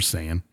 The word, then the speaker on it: Saiyan: Super Sega. – Super Sega Genesis, yep. – Can't handle Elder Scroll or Elden Ring fucking mechanics and then can't even pronounce Super Saiyan.